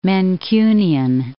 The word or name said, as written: Mancunian